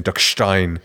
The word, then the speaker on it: Duckstein: When I was in Hamburg, I don't think I mentioned it, but I tried Duckstein, I guess it would be mm-hmm. with the Schwäbisch pronunciation, Duckstein, and it's not very good.